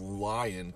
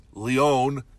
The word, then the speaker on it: Lyon: I grew up in a county called Lyon County, which is supposed to be pronounced Lyon, because it's a French fella. L-Y-O-N.